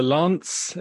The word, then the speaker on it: Lainz: So it all happened in the Lance, it's spelled L-A-I-N-Z, and I've consulted Google Translate, and it says Lance.